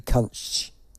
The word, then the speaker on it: Kuntzsch: Go to Jodie Kunch. It's an un. I don't. Listen, that's the one. That's how it's pronounced.